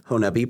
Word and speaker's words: Honobia: By the way, Honubby, pronounced spelled H-O-N-O-B-I-A. If you've just seen it on a map, you might think Honobia. Honubby.